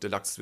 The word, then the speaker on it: Deluxe: U sagst, ist es eigentlich Englisch und Deluxe wäre tatsächlich die richtige Aussprache.